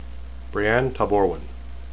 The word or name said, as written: breane